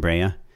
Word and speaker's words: Brea: We pronounce it Brea.